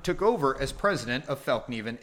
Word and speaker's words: Fällkniven: Um, but the reality is, it is pronounced Fällkniven, pressure on first and second syllables where ni is pronounced and an audible k and ven pronounced as in raven without the ra of Raven.